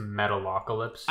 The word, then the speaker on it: Metalocalypse: Metal Apocalypse, and Venture Bros. – Can I be a ninja, bro? – I'm pretty sure that one's pronounced Metalocalypse.